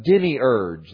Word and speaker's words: demiurge: Gnosticism labeled him the demiurge. That's how you pronounce that.